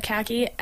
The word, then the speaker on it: Khaki: and he said Khaki, and my parents just ate that. – Oh, not Khaki, but yes, oh. Khaki.